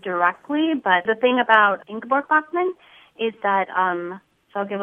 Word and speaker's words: Ingeborg Bachmann: Clearly, you have a poetic and probably even an emotional relationship with a German poet, and I think I might be pronouncing her wrong, Ingeborg Bachmann? – Yeah, yeah, yes. – Ingeborg Bachmann.